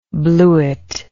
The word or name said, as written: blewit